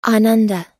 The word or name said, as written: ananda